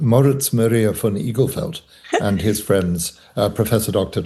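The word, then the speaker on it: Igelfeld: Eaglefelt.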